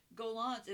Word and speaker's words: Gollancz: Gollancz, it's spelled G O L L A N C Z.